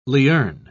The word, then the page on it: lierne: British English